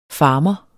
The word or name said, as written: FARMERENS